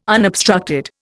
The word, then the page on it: unobstructed: Indian